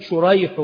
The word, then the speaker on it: شريح: وأما اسم الأبوين فقد اتفقا خطا ونطقا، النعمان والنعمان، ولكن شريح بن النعمان وسريج بن النعمان، الأول بالشين المعجمة والحاء المهملة وهو تابعي يروي عن علي رضي الله عنه، والثاني وهو سريج بن النعمان بالسين المهملة والجيم، هو من شيوخ البخاري، فهو النوع الذي يقال له المتشابه.